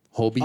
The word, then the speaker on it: Holbein: I mean, that's how I would pronounce it. Yeah. Holbein. – Oh, okay. I thought you meant like the word whole and then beans.